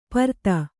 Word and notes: ಪರ್ತ: parta

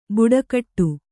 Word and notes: ಬುಡಕಟ್ಟು: buḍakaṭṭu

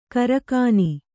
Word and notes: ಕರಕಾನಿ: kaakāni

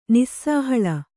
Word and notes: ನಿಸ್ಸಾಹಳ: Kannada

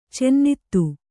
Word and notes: ಚಿನ್ನಿಧಿ: cinnidhi

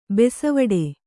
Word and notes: ಬೆಸವಡೆ: Kannada

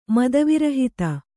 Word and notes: ಮದವಿರಹಿತ: mada virahita

